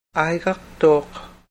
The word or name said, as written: airaqtuuq